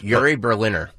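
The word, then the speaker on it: Uri Berliner: Is it Yuri Berliner? – Yes. – Yuri Berliner. – Yuri Berliner. – Yeah. Yep. – So Yuri Berliner comes out and hammers public radio's liberal bias. Now, Yuri Berliner has worked at NPR for 25 years.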